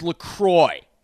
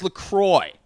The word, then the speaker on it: La Croix: So, anyways, we're talking about it as we're going, and I hear from behind me, it's La Croix.